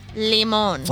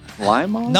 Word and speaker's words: Limon: L-I with a accent, M-O-N. Mm. Flaming Limon. – Flaming Lemon. – Flaming Lemon. – Try them. – Or is it lime? Limon? – No, it's limon. It's limon. – Is it limon? That's how it's spelled.